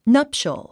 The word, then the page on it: nuptial: nʌ’pʃəl; ナプショゥ